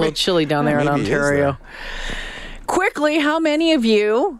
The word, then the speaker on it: Ontario: I'm like, I think it's pronounced Ontario. And he was like, oh, thank you for that.